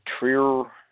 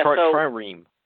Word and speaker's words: trireme: A trireme. Yeah, trireme.